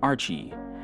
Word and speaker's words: RCHIE: What you're listening to right now is Hollywood by Archie. I believe that is how it's pronounced. It's spelled R-C-H-I-E.